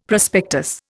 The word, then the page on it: prospectus: Indian